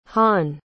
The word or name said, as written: hanh